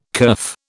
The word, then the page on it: kerf: British English